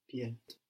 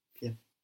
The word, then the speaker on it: pied: Pied. Pied. – Pied. Pied. The D on the end, we don't pronounce it, no? Pied.